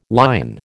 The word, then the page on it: lion: American English